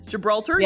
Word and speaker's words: Gibraltar: So Helen and her boyfriend David are touring the caves under the rock of, is it pronounced Gibraltar? – Gibraltar, yeah.